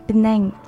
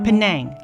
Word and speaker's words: Penang: Um, uh, as, as far as I can tell, um, when I was doing the Da Ai TV news for English, um, even Penang is pronounced uh, slightly differently um, for Malaysians in different region.